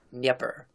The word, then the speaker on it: Dnieper: So you don't actually say Dnieper? – Yeah, it's the one that's spelled D-N-I-E-P-E-R, is something like Dnieper.